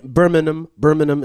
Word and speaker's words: Birmingham: Birmingham is the correct way to pronounce it.